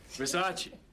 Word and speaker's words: Versace: It's Versace. – What? – Versace. It's pronounced Versace.